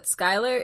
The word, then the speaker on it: Schuyler: I just want to say that Skylar is spelled confusing. Can someone explain to me why Skylar is spelled like Schuler? – Yeah, I never pronounced that right.